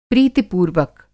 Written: प्रीतिपूर्वक

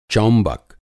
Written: चौंबक

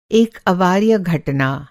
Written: अवार्य

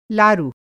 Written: लारु

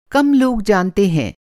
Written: कम

